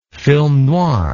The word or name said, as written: film noir